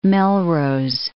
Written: Melrose

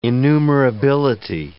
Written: enumerability

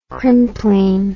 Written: crimplene

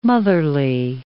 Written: motherly

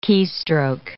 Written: keystroke

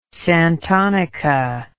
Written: santonica

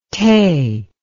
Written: tae

